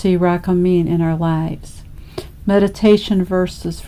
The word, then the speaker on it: Rachamin: The sound uh, in the Hebrew, rachamin, is uh, pronounced similar to the word jalapeno, so it's rachamin uh, it's a noun, but it can also be used as a verb, as it often happens in prayer.